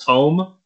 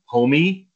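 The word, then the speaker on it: Homme: Last name pronounced home, homie. – I think it's homie. I've always said home. Okay. I think I saw somewhere it is actually homie.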